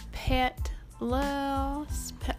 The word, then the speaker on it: Petlas: So with all that said, we're going to start in with the first app, and I want to say it's Pet Loss, it's almost French, like Pet Loss, Pet Loss. It's not petless, because we're not. We have pets, but it's oddly how you say it. But anyway, the app is P-E-T-L-A-S, and it's a great community app.